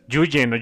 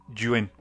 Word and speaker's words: Nguyen: Yuen. – No es Yuyen o Yuen, es como Gwen, pero con una G-Y, Yuen. – Yuen.